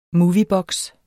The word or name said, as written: MOVIEBOKSE